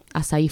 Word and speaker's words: Asai: Me pueden buscar, como me llamo, Azaí Ferrari. – ¿Cómo se escribe Azaí? Con A-S-A-I.